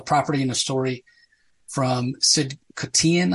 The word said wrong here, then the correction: Kotian: I hope I'm saying that right. Uh, Koshin. Okay, Koshin. Hmm.